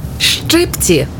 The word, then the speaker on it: щипці: Щипці чи щипці? Як правильно? Правильно, щипці. Наголос на «и».